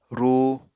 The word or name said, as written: ruu